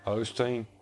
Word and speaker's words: Osteen: Osteen, Olstein, whatever fuck, Osteen. I can't ever say his name right. How do you say it? – Osteen. Yeah, something like.